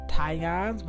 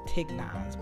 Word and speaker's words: Tignon: It's called Tigons, but it's spelled T-I-G-N-O-N. It looks like it's pronounced Tignons, but it's really Tigons law.